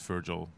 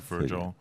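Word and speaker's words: Feargal: Dus eigenlijk zou het zo uitgesproken moeten worden, maar het is Virgil. Zeg gewoon weer lekker Virgil.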